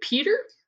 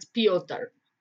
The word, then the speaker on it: Pyotr: Yes, last week we were discussing how to pronounce the spelling P-Y-O-T-E-R. Is it Peter? Or is it? – I think that's the anglicized, yeah. – But it's Pyotr. – Yes, with the roll at the end.